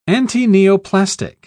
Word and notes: antineoplastic: British English

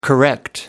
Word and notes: correct: American English

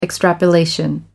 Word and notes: extrapolation: American English

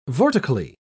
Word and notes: vortically: British English

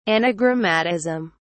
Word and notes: anagrammatism: British English

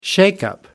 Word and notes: shakeup: British English

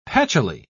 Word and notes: patchily: British English

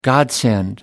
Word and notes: godsend: American English

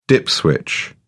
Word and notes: dip switch: British English

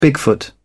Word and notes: bigfoot: British English

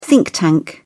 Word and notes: think tank: British English

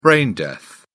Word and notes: brain death: British English

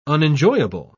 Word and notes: unenjoyable: British English

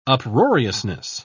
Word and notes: uproariousness: British English

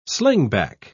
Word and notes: slingback: British English

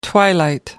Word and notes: twilight: American English

